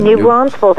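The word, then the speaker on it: New Braunfels: New Brunsfields, actually, yes.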